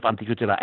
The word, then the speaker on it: Antikythera: Antikythera. – Yeah. – Like Antikythera is a small island before Greece. And in 1919, they found some divers found an object which is called the machine of Antikythera.